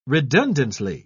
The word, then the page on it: redundantly: British English